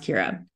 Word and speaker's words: Ciara: It is an Irish name, so I know that it doesn't look like it's pronounced Kira, but I promise it is pronounced Kira.